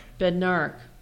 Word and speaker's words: Bednarik: Bednarik.